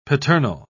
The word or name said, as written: paternal